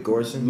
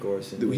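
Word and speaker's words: Göransson: Is it Grossum? Ludwig Grossum? – Oh yeah, Ludwig Gorson. – Ludwig Gorson.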